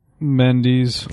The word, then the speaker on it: Mendes: Changing gears for a second, I saw today a story in the on the internet making the rounds, an interview with Sam Mendes, or Mendes. How do you say Sam's name? – Mendes.